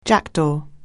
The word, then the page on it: jackdaw: anglais